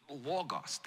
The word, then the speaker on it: Logos: Logos, that's how you say it in Greek.